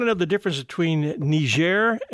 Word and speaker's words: Niger: France got the area that we call Niger. – Okay. – That's why it's pronounced that way, like a French word.